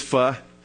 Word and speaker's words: pho: It is pho. You're letting a German Italian correct you and your Vietnamese. That ain't right. She's just trying to mess with me. You could go out and have pho.